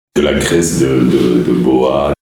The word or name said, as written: Graisse de boa